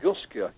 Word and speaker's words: Tunguska: The Tunguska. Is that how you pronounce it? – Tunguska.